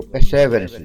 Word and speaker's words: Perseverance: rover Perseverance, ou Perseverance, que é a pronúncia mais correta, e o helicóptero Ingenuity, o Ingenuity, em um foguete Atlas V da United Launch Alliance.